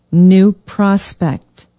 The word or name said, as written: new prospect